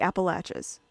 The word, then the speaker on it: Appalachia: the Appalachians. And yes, that's how you pronounce it, Appalachia.